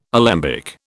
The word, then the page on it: alembic: British English